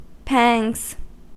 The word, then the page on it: pangs: American English